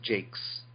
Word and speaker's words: Jacques: Jacques? – I believe it's pronounced Jakes.